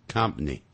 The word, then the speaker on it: company: 这三个句子里头我特别提到 company 这个字啊，念快的时候会念成 company, company, 可是念慢的时候是 company。